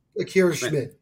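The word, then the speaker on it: Akira Schmid: Is this, speaking of goaltenders, assuming I say his name right, because I saw him in Utica a little bit. Akira Smid. I think I got his name correct. – Akira Smid. Yeah.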